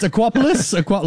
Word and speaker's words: Aquapolis: It's Aquapolis, like Acropolis.